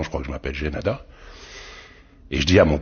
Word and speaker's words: Jaenada: Par exemple, tenez, votre camarade Philippe, Felipe, on l'appellerait en Espagne Felipe Jaenada. ici on l'appelle Philippe Jaénada.